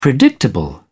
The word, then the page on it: predictable: British English